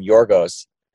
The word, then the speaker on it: Yorgos: my official pronunciation, I've had a couple Greeks comment complimenting us for calling him Yorgos and not Georgios.